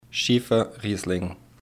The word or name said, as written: Schiefer Riesling